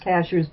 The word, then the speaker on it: Cashiers: Cashiers is pronounced cashiers, but it looks just like a cashier at a store.